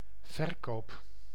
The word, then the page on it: verkoop: [vǝɾ.koːp]; /vərˈkoːp/; /vǝɾ.ko:p/